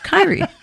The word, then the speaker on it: Kyrie: But it is not pronounced Kyrie. It is not. – Yeah. – It's pronounced Kyrie.